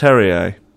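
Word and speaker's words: Terje: He's pronounced Terrier.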